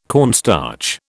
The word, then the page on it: cornstarch: British English